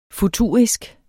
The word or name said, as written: futurisk